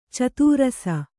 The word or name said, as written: ಚದುರಿಸು